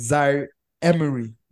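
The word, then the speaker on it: Zaïre-Emery: And he's somebody that like a lot of people have had, actually had their eyes on, like Zaire Emery. That's his name. I know I didn't pronounce it well, but I feel like I'm with Nnamdi on that one. – You got it bang on, man.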